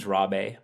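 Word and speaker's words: Rabé: He's all, and it's funny because my last name is Rabe, but you know, in Portuguese, they pronounce a lot of R's as like H's.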